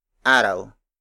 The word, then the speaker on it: araw: An example of a root word is araw. Araw. Araw. Araw is spelled as A-R-A-W.